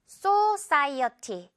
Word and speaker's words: society: 역시 앞에 O 있는데, society. 이렇게 너무 so 강하게 하지 않게 주의를 하세요. society. 거의 so에 가깝지만, 그것조차 굉장히 좀, 굉장히 가벼워요. so. 깃털 발음. society.